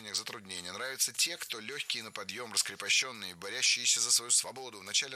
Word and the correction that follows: борющиеся: Борящиеся неверно, причем в этом слове сразу две ошибки. Во-первых, ударение должно падать на первый слог. Во-вторых, в этом слове суффикс «ющ», а не «ящ». Борющиеся – это правильный вариант.